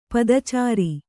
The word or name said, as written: ಪದಚಾರಿ